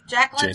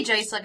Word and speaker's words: Jacelyn: How do we say his name again? – I don't know. – Jacqueyn? – Jacelyn, I think. – I say Jacelyn, because Jacqueyn is a lady name.